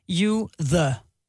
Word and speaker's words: Jytte: Det er meget sjovt, fordi inde på hendes er det Instagram eller hendes Facebook, så står der så pronounced you the. – You the. – You yeah. the. Mm. Så THI, ikke? The.